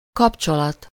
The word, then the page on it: rapport: France; /ʁa.pɔʁ/